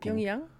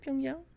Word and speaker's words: Pyongyang: ¿Estoy pronunciando correctamente Pyongyang o cómo? – Pyongyang. Sí, Pyongyang.